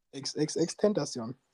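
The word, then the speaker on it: XXXTentacion: XXX Tentation.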